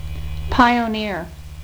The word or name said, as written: pioneer